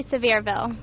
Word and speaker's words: Sevierville: Tell me a little bit about, this is Sevierville. – Yes. – Did I say it right? – It's actually Sevierville. – Oh, severe, like a severe toothache.